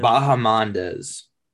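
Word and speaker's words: Bahamondes: But Ignacio, how do you say his last name? – Bahamandez.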